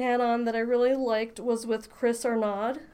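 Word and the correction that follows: Arnade: Am I pronouncing that correctly? – I didn't know this, but I believe it's Arnaudy.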